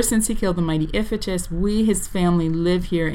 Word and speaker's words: Iphitus: So she mentions here that Heracles has killed the mighty, I'm going to blank on the pronunciation, Iphitus? – Yep.